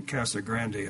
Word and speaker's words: Casa Grande: He was living in Casa Grande at the time, that's the way they pronounce it, Arizona, since moved to Mesa, Arizona, and I'm not sure if he's still there or what, but hopefully we'll hear from him.